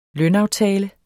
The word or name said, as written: lønaftale